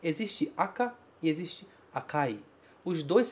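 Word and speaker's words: aka (赤): Ele se pronuncia aka, vermelho.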